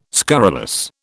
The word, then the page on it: scurrilous: British English